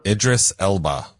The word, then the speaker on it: Idris Elba: And he is trying to get her to make, who's the Erdis? I always mispronounce his name. What's his name? – Idris Elba.